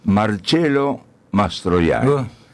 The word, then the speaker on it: Marcello Mastroianni: Pero vamos a pronunciarlo correctamente, Marcello Mastroianni.